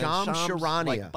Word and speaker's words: Charania: But moving on, Lonzo Ball had his knee surgery, and Shams Charania. I can never say his name. – Shams Charania.